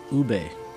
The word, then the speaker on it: ube: Now, if you're familiar with Filipino desserts, there's a flavor that's very popular that's called ube. It's spelled U-B-E. Ube.